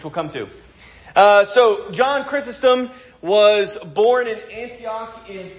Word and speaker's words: Chrysostom: How to pronounce his last name? And spoiler alert, it's not his real last name. – Chrysostom. – Chrysostom? Close? Close enough. Chrysostom. Chrysostom.